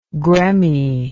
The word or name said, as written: gremmie